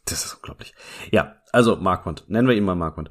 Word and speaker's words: Marquand: Ich, ich, bin mir immer nicht sicher, ob das Marquand oder Marquand ist. Marquand? Ich habe früher immer Marquand gesagt, bis ich dann mal irgendwann neulich eine Doku gesehen habe, wo sie es äh, weniger französisch ausgesprochen haben. Und es Marquand. ergibt ja auch keinen Sinn, dass der französisch ausgesprochen werden sollte. Ich glaube, der ist Waliser.